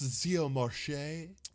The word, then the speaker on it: Xiomarche: I want someone like the fancier person because it's spelled, you know, Xiomarche. It's like, it's pronounced Xiomarche.